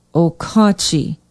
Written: okauchee

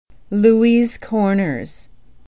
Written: louis corners

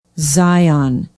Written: zion

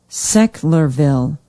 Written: Sechlerville

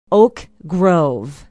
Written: oak grove

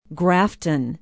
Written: grafton

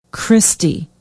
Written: christie

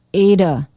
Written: ada

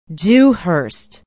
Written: dewhurst

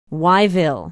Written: wyeville

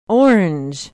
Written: orange

